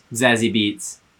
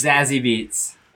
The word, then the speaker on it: Zazie Beetz: Zazie Beetz. Let the jury's out on how it's pronounced. – Zazie Beetz.